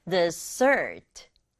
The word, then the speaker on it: dessert: Dessert. Dessert. Dessert, 우리말은 디저트라고 하는데 영어는 dessert. Dessert 이렇게 발음하게 돼요.